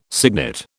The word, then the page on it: signet: British English